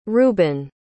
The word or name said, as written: reubin